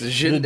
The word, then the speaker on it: Genndy: I want someone to show up and be like, oh, his name's Gendy. And be like, oh, okay. I'm just waiting for it. It's Gendy.